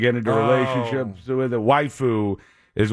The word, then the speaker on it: waifu: And remember, it's pronounced waifu, not waifu. Waifu? Like wife, but foo. – Oh, okay. It's spelled W-A-I-F-U.